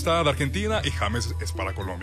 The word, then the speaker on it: James: Se pronuncia James.